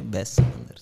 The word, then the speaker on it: Wes Anderson: Se tu mi dici che si dice Wes Anderson, ti chiedo scusa, ma si dice Wes Anderson.